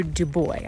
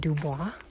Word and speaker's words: Dubois: This stretch was from Pinedale to Dubois. I was pronouncing it Dubois, but apparently the locals say Dubois, Wyoming.